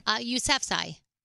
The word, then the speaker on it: Yousafzai: Yousafzai.